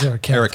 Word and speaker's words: Eric Kath: I haven't pronounced this right. Eric Kath. – Yeah, Eric Kath.